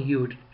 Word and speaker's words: Judt: In a recent Washington Post Newsweek blog, Yute, and his name, by the way, Tony Yute, his last name is spelled J-U-D-T, Yute, or some might say Jute.